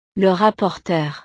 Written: le rapporteur

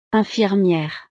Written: infirmiere

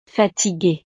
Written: fatiguee

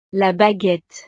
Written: la baguette

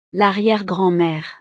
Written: l'arriere-grand-mere

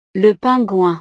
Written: le pingouin

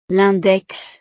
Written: l'index

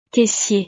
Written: caissier

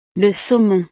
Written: le saumon

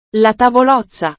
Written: la tavolozza

